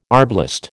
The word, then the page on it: arblast: American English